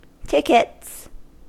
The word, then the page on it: tickets: American English